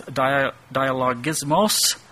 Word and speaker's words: dialogismos: That word doubting there is dialogismos, the emphasis on the last syllable there.